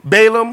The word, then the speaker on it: Balaam: Balaam.